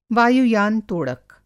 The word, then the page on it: वायुयान तोड़क: Hindi